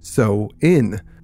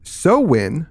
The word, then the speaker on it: Samhain: And most people seem to like it, but I did get schooled on my pronunciation here. And of course, that's on me. Sam Hain. – A lot of people wrote in about that. – Yeah, yes. They said, "You did it wrong. It's actually pronounced so in."